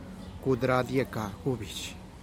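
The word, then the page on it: kud riduʼb gubich: Teotitlán del Valle Zapotec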